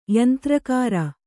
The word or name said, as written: ಯಂತ್ರಾಗಾರ